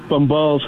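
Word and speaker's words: Bombal: It's Mateus Bombals Hollywood. That's spelled M-A-T-I-A-S B-O-M-B-A-L Hollywood, like the city. One more time, it's M-A-T-I-A-S B-O-M-B-A-L Hollywood, like the city, and I'd be happy to have you join me there for exciting pictures and fun movie facts.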